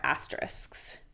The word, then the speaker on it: asterisk: He released the list with all of the songs are asterisks. Asterisks. Mm-hmm. Asterisks? Is that how you say it? – Asterisk. – See? – Asterix. – It's the ending that's hard.